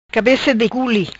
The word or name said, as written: Cabeca di culi